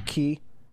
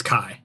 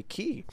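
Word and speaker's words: Chi: Yep, that's pronounced Kai. It's Koine Greek. Nobody really knows how it's pronounced, but that's the accepted right. pronunciation.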